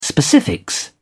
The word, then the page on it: specifics: British English